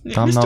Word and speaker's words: Domhnall: How do you say his name? – Domhnall? – Is it Domhnall? – I think so. – Domhnall Mr. Gleason.